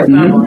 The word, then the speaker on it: Babylon: Yeah, Babylon.